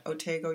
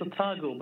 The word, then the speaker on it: Otago: You're now a visiting professor of Scottish Studies and Crime Fiction at Otago University. And did I pronounce that right? – I think it's Otago, but let's not quarrel about it.